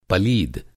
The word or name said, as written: पलीद